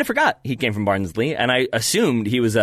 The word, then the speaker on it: Barnsley: Barnsley.